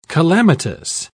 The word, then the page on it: calamitous: British English